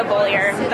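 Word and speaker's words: Beaulieu: Yeah, my name's Rebecca Bollier. I should spell the last name. It's B-E-A-U-L-I-E-U.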